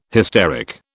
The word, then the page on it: hysteric: British English